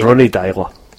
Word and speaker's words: Deila: Ronny Deila.